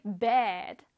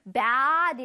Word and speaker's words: bed: 이거 잘못하면 bad 잃어버리면 나쁜 이란 뜻이 돼요. 그럼 안 되겠죠, 그쵸? bed. bed 할 때 옆에 이 광대를 위로 슉 위로 솟는다라는 느낌으로 발음해 줘야 돼. bed 양옆으로 입을 쫙 늘려서 bad 이러면 나쁜이란 뜻이야.